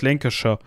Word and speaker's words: Lancashire: das, das, das spricht man fast gar nicht mehr, dieses Shire spricht man gar nicht mehr richtig aus, man sagt Lancashire. Äh, also man, selbst die Engländer verwursten das ja hinten so, also das, das wird ja so pff, komplett weggekürzt.